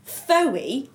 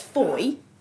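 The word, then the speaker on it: Fowey: Like the word phoey is pronounced foy.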